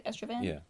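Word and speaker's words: Estraven: But basically, Estravan, is that how you say it? Estravan? – Yeah.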